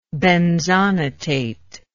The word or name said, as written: benzonatate